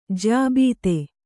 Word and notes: ಜ್ಯಾಬೀತೆ: jyābīte

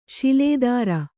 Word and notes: ಶೂಲಧರ: śula dhara

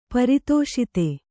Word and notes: ಪರಿತೋಷಿತ: pari tōṣita

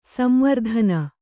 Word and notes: ಸಂವರ್ಧನೆ: kannada; samvavardhane

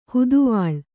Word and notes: ಹದವಳಿ: hadavaḷi

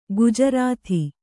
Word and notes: ಗುಜರಾಥಿ: gujarāthi

